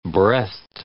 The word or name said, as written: breathed